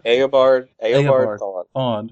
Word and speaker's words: Eobard Thawne: Elabor? – Eobard Thawne.